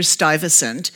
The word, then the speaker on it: Stuyvesant: I'm sure that's not how it's pronounced in Dutch, but that's how we pronounce it in New York. Uh, we have Stuyvesant Place, Stuyvesant School, Stuyvesant uh, Street, so to us, he's Peter Stuyvesant.